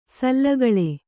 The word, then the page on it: ಸಾಲುಗೊಳ್ಳು: Kannada